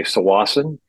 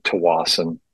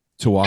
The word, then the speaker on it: Tsawwassen: And then, you know, if you're listening to CBC or you're taking the ferry, they'll say Sawasan, but everyone who lives there calls it Tawasan. Tawasan. – Tawasan. Oh, okay. Yeah. All right. Silent S.